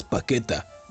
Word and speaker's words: Paquetá: La selección de Brasil llenó de alegría el vestuario en Qatar, gracias al ritmo de los jugadores Thiago Silva y Lucas Paqueta. ¿Paqueta paquete? No es Paqueta. Tiene acento en la.